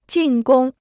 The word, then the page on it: 进攻: jìn gōng